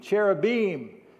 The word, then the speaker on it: cherubim: After he drove the man out, he placed on the east side of the Garden of Eden, cherubim, that's how you say it in Hebrew, and a flaming sword flashing back and forth to guard the way to the tree of life.